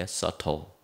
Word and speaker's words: subtle: B는 묵음으로 읽어야 돼요. subtle 잘 안 보이는 포착하기 어려운 아래에 있는 뜰채처럼 생긴 그물.